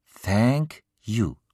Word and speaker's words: Thank you: Höre genau hin. Thank you. Thank you, wiederholt Simon ganz richtig, und Frau Beusen ist begeistert. Richtig, ganz haargenau richtig. Das hast du prima gemacht, Simon. So spricht man das TH aus.